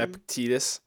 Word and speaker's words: Epictetus: Epictetus? How do you say his name? – Epictetus.